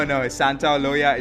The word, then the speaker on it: Santaolalla: No, Santaolalla is the correct way to say it.